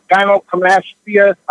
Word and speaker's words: gynecomastia: And also, one more thing, he has, I guess, I think it's called gynecomastia.